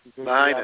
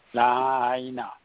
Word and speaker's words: Lahaina: Anyway, tonight we are talking about what happened in Maui, in I think we pronounce Lahaina. – Lahaina. – We have a double dot on the I. Lahaina.